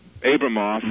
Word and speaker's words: Abramoff: Abramoff, right? – Did I say his name correctly? – Yes.